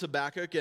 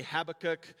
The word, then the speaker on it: Habakkuk: Truly, the pronunciation of this word is sort of up for debate. It's not even a traditional Hebrew name. It's what they call an Arcadian loanword. So you can say Habakkuk, you can say Habakkuk, and that'll be fine.